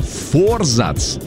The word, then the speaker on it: форзац: Так вот, действительно, форзац считается правильным ударением.